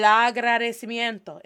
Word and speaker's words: agradecimiento: Agradecimiento.